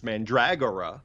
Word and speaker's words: Mandragora: That's how he pronounces his name, and very often our names, you know, people's names are not pronounced as they are spelled. – Right. – So we have to accept that it's Mandragora.